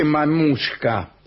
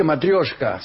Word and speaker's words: matrioshka: Sí, puede ser, Hola, no se dice mamushka. No existe esa palabra en ruso. Yo dije matrioshka.